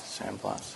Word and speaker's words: San Blas: San Blas.